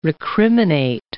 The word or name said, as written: recriminate